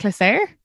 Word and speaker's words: Clisare: You go by, how do you say that, Clisare? – Yeah, Clisare, yeah.